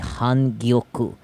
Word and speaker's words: Hangyoku: Maiko is spelled M-A-I-K-O, Maiko. Or if you're going with the variation in Tokyo, it would be Hangyoku. H-A-N-G-Y-O-K-U. Important thing to note here, there is no difference between a Maiko and a Hangyoku.